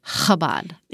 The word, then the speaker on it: Chabad: Okay, this is my favorite, is one woman said, if you feel like you're about to spit, you're saying it correctly. Chabad.